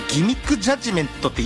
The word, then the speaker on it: 欺瞞裁判: 欺瞞裁判と書いてギミックジャッジメントって読ませるやつがこの世の中にいるんですよ。